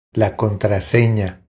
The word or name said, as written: La contrasena